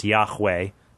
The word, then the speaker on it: Yahweh: You don't want to curse or blaspheme or disrespect God's name, so they would just abbreviate A-A-H-R-A-A-H with the consonants YHWH, which is believed to have been pronounced Yahweh.